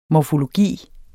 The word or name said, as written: morfologi